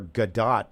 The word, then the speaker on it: Godot: No, I mean, I'm interested in that because they recently did Waiting for Godot, apparently is how you say that.